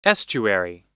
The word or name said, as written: estuary